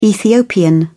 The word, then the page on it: ethiopian: British English